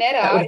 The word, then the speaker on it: Neda: It's T-E-N-I-E-L-L-E. – Yeah, yeah. Yeah, and Neda is actually my middle name. And it's okay. N-E-D-A.